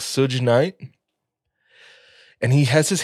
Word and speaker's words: Suge: Suge Knight.